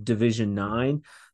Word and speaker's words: Division 09: For our listeners, it's division, it's pronounced Division 9, but it's Division 09, kind of like a little nod to the Duke boys there with the 01.